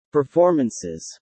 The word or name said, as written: performances